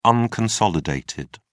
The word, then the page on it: unconsolidated: British English